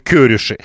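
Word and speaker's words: Kőrösi: Так вот, ударение на первом слоге. И поэтому Чаба, и поэтому Кёриши.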